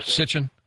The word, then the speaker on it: Sitchin: Is that how you say it? – Sitchin. Sitchin.